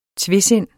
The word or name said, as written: TVESINDET